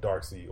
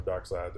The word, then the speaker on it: Darkseid: Parademons are the minions and foot soldiers for Darkseid or Darkseid, depending on everybody has the different pronunciation for Darkseid.